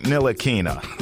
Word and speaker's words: Ntilikina: In fact, it was him and Frank Ntilikina. So Frank was... – You actually pronounced that right.